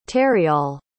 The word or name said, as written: terryal